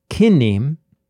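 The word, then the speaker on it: kinnim (קִנִּים): Or the word could be pronounced kinnim, in which case the meaning of kinnim is not reeds, but it's also not rooms.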